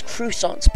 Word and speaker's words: croissant: You Americans, you say it as croissants, but I'm going to tell you, you actually say it as croissants. That's the way you actually pronounce it. It comes from the French word, croissant.